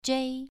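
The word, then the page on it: zhei: tone 2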